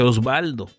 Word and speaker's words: Oswaldo: Y pues Oswaldo Sánchez, ¿verdad? Nomás que lo que no recuerdo si es Oswaldo, se escribe así y a veces se dice Osvaldo, pero Osvaldo va con B.